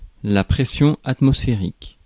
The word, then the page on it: pression atmosphérique: /pʁ[ɛ/e].sjɔ̃ at.mɔs.fe.ʁik/